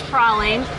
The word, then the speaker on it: praline: I just called Corey Martin to confirm the correct pronunciation of this treat. And he said definitely it was praline.